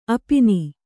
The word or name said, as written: ಅಪಿನಿ